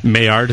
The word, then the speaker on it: Maillard: Maillard, is that how it's pronounced? – Yeah, that's a problem with only reading books.